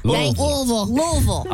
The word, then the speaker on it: Louisville: Y'all got to say it right. Louisville. – My bad. – Louisville.